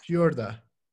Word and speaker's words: Fiorda: So Fiorda. It's Italian, actually. – Fiorda.